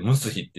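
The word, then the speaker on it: 産霊: 産む霊魂の霊って書いて、ムスヒって読むんですよ。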